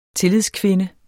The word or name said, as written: tillidskvinde